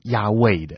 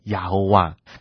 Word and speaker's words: 亚卫: 因为当学者去还原整个当时的母音的时候，根据查考，其实呢是应该读成亚卫的。所以在旧约当中，其实神的名字是亚卫，而不是把这个阿多奈的母音呢硬生生的塞到原本这个亚卫的子音的部分，念成亚侯哇。